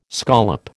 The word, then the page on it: scollop: American English